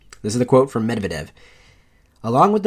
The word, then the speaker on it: Medvedev: On December 7th, 2012, then Russian Prime Minister Dmitry, Medvedev. – Medvedev. – There you go. Medvedev.